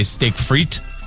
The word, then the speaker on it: frites: Did you know the French don't even pronounce the S? They just say steak frites? – Yeah.